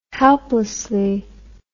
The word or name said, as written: helplessly